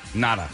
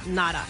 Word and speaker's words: Ngata: I believe that the G is silent. – Okay, so what is it? – Nada. – Nada.